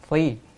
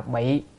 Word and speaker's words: phi: chữ này đọc là phi, đem quần áo mặc vào người, không đọc là bị mà đọc là phi.